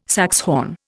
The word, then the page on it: saxhorn: Indian